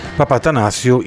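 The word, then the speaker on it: Papathanassiou: anzi Vangelis si pronuncia, Papatanassio, immenso musicista di musica elettronica e di colonne sonore immortali.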